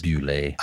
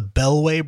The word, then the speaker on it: Boulet: I called this the Belway brothers, and well, oh, I don't I know why. That. I just completely. it doesn't it's not spelled like that at all. No. but for some reason, my mind flipped the L and the W, and for years I called it the Bellway Brothers, but it's the Bewlay Brothers.